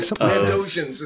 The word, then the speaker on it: Mondoshawan: The Mondoshawan? – Mendojans? – A fan. – Mendojans is how they pronounce it.